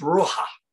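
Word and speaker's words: Ruha (רוח): Now, another word here that is frequent in Hebrew is a word that is spelled R-U-A-H, and it's pronounced Ruha, Ruha.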